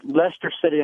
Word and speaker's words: Leicester City: But I have a quick question, and I'll hang up and listen, concerning Leicester City, and I hope that's the correct pronunciation. – Yes, it is.